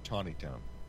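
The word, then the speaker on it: Taneytown: Yeah, they pronounce it Tawnytown.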